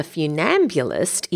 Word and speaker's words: funambulist: And tightrope walking does sound fun and a little bit terrifying, but it's actually pronounced funambulist because it's from the Latin funus, which means rope. And of course, ambulist comes from the Latin ambulare, meaning to walk.